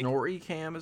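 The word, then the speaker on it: Snorricam: Snorri cam is how it's pronounced.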